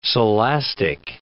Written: Silastic

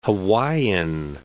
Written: Hawaiian Islands

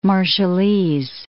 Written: Marshallese

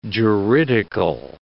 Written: juridical

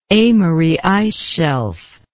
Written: amery ice shelf

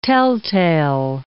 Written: telltale

